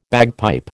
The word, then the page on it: bagpipe: American English